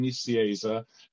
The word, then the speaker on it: Nicieza: The way I say it is in Spanish, it's Fabian Nicieza. In English, it's Fabian Nicieza. And often in American, it's Fabian Nicunza.